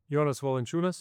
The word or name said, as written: Jonas Valanciunas